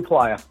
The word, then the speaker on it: Plaia: Lou Plaia.